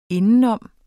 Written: indenom

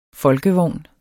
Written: folkevogn

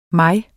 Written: mig

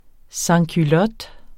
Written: sansculot

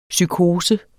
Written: psykose

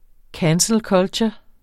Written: cancel culture

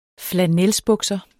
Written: flannelsbukser

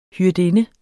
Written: hyrdinde

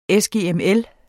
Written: sgml

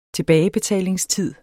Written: tilbagebetalingstid

